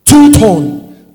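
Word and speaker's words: touton (τοῦτον): This in Greek is known as touton. Touton. T-O-U-T-O-N, but it's pronounced touton.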